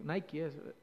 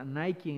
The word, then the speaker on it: Nike: o Nike, no sé, Nike es, la pronunciación correcta, Nike en inglés, de ahí toma de la diosa griega que, que significa victoria, ¿no?